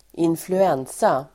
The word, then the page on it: influensa: [²influ'en:sa]